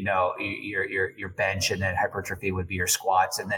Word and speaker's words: hypertrophy: hypertrophy. I never can pronounce that word. – Hypertrophy.